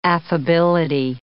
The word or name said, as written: affability